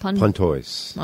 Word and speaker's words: Pontoise: Pontoise.